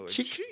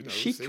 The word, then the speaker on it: Chic: Ils regardaient le nom du groupe, on prononce chic. Non, non, non, on dit chic, on est français.